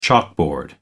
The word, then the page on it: chalkboard: American English